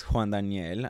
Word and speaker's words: Juan Daniel: Yeah, but my full name is Juan Daniel.